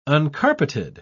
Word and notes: uncarpeted: British English

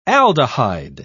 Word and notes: aldehyde: British English

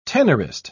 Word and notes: tenorist: British English

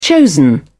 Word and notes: chosen: British English

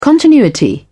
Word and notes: continuity: British English

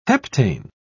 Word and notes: heptane: British English

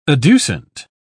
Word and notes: adducent: British English

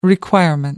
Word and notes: requirement: American English

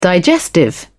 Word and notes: digestive: British English

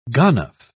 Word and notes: ganof: British English

